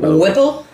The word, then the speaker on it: Whipple: Whipple? – Whipple. – W-H-I-P-P-L-E. – Whipple. – Whipple. – It rhymes with nipple.